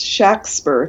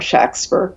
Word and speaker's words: Shakespeare: Probably it was pronounced Shakespeare if you look at how it was spelled in surviving documents, with a short A, S-H-A-C-K-S-P-E-R, or S-H-A-X-P-E-R, Shakespeare.